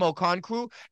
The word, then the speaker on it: Okonkwo: How do you say that? – Don't I have Okonwu? – Chigozem Okonkwo.